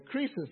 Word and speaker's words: Croesus: In fact, they had a king, King Croesus, that's how it's pronounced, was the richest man of the world at that time.